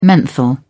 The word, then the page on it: menthol: British English